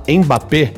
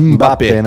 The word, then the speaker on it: Mbappé: A gente fala Mbappé e não é Mbappé. – É Mbappé, né? Mbappé. – É o M e o B é um som só.